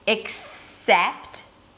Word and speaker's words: except: So it begins the same, except, the second syllable is sept, and in the word expect, the second syllable is spect.